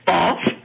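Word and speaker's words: Bawlf: Isn't there a town called Balf? I think it might be spelled B-A-W-L-F. I could be mistaken, but I think there's a town in Alberta called Balf, Alberta. And that rhymes with golf.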